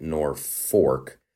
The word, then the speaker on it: Norfolk: It's spelled Norfolk, but they pronounce it Norfork.